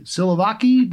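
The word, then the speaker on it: siloviki: I'm just wondering, you know, the people, or no, you've reported on for years, the people around Putin, the Slovaki, Silovaki, do I pronounce that correctly? Yes. Uh, mm-hmm.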